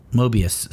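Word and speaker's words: Möbius: Moebius? How do you say that word? – Mobius strip. – Mobius, thank you.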